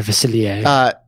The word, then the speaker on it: Facilier: Dr. Facilier, is that how you say it? – Dr. Facilier.